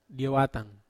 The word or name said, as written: diawatan